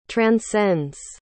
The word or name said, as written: transcends